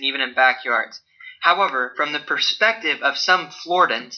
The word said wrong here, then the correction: Floridians: is that how you say it? Floridians. Sorry, Floridians.